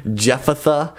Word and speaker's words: Jephthah: Jephthah.